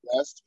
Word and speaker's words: breast: Okay, it's a word like yeast and breast. Because like you know, yeast is pronounced yeast, and then the word coming up next would be breast, but you pronounce it breast.